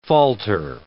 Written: falter

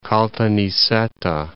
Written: Caltanissetta

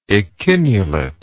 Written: echinulation

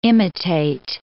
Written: imitate

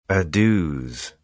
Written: adieux

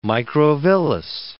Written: microvillus

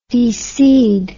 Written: deseed

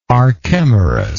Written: archemorus

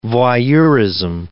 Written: voyeurism

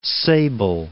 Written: Cape Sable Island

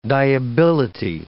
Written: dyeability

